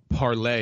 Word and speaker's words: Parler: Yeah, so Parler is actually how it's pronounced. – Oh, Parler. – The French word.